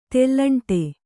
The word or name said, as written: ತೆಲ್ಲಂಟೆ